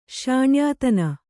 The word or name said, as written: ಶ್ಯಾಣ್ಯಾತನ